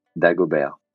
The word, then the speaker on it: Dagobert: It's like bear, like a bear, like an animal. So yeah, Dagobert.